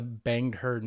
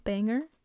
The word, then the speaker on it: Bangor: Because this story was covered by the Banger News. – Not, how do you spell it? – B-A-N-G-O-R. – Did not the Banged Her News? – Did I say that? Banger? – Bangor? No, you didn't.